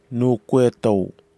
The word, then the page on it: noukwetao: Kwamera